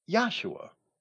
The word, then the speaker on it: Yahshua: And once we understand there was no English J in either Hebrew or Greek, Joshua can only be properly pronounced with a Y sound. Yahshua.